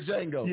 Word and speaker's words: Django: That was like Django say the D is silent. – Right, it was a D. – It was in a D in front of the J. – The Django.